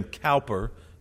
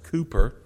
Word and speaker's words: Cowper: Many of us call him William Cowper. His name is actually pronounced Cooper. But he is an interesting man with an interesting story.